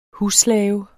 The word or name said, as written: husslave